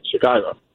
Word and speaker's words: Chicago: It's like, oh, you say Chicago, very funny. I'm like, no, that's how you say it, Chicago.